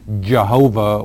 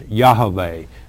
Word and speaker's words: Yahweh: Well, in the original languages, there is not an equivalent to the letter J in the Hebrew language. So obviously, if you're going to pronounce his name correctly, it can't be Jehovah with the J. The correct pronunciation of our Heavenly Father's name is Yahweh, and that's locked in acrostics four times in the book of Esther, and we'll get to eventually one time in the Psalms.